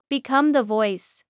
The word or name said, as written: Become the voice